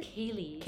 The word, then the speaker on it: ceilidh: Kaylee. And no one could ever spell this. C-E-I-L-I-D-H.